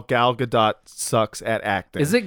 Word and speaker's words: Gal Gadot: So what I was saying, Gal Gadot, first of all, that is how it is pronounced, Vince. It's pronounced Gal Gadot.